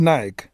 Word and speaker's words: Naik: So tonight, we meet Lee Naik. Not Nike, guys, uh, but Naik.